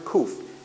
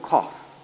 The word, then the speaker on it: qof: That 100 mean is the Hebrew letter kuf, Q-O-F. Some people pronounce it kof, which simply means 100.